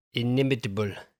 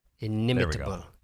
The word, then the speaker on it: inimitable: I know that word to see it and to hear it, but I don't know if I pronounced that word right. – Inimitable. Inimitable. – That's what I was going for.